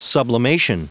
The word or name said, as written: sublimation